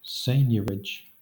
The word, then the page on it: seigniorage: Southern England; /ˈseɪnjəɹɪd͡ʒ/